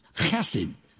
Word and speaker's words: chesed: It's a Hebrew word translated kindness, chesed. if you say it right, something lands on the back of the neck of the person in front of you. So be careful of trying about repeating it. Chesed is a guttural term.